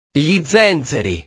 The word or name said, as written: gli zenzeri